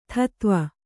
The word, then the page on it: ದೀದಿವಿ: dīdivi